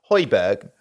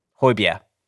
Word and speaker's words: Højbjerg: Now, I'm going to pronounce it Højbjerg, but apparently that's wrong, isn't it? – Højbjerg. – Sure. – Is what I've been told.